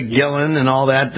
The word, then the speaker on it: Guillen: Michael Guillen, is that correct? – Well, you know what, Bill? I'm a kid from East Los Angeles, and in Spanish it's Guillen. – Guillen, yes. I know we went through this before.